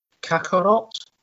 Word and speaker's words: Kakarot: 17, Dragon Ball Z Kakarot.